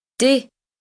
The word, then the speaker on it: des: Des. Des. – Des. Okay, real good. That's spelled D-E-S.